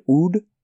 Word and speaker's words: Oud: O-U-D is the word. Oud? – Oud.